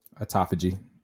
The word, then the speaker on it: autophagy: The question was, what is autophagy? Am I saying it right? – Autophagy.